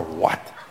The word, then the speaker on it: what: Is that nauseating or what? Joining me now. – Okay, stop. I think that the main sign of being old is pronouncing the H on what? – What?